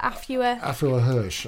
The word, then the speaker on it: Afua Hirsch: How do you say that? Afua Hirsch, I think. – Afua Hirsch. – Yeah.